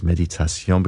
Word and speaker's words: Méditation: or should pronounce it in French, of course, Méditation, because this is from French composer Jules Massenet, and it's one of his most famous pieces, if not the most famous.